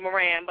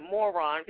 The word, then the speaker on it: Moran: I'm sure it's supposed to be Moran, but I'm pronouncing it Moron because it's M-O-R-A-N.